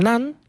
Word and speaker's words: naan: Sí, nosotros tenemos los panes tradicionales, que es el naan, que se escribe con N-A-N, que a veces la gente lo termina pronunciando con M, naan, pero es con N.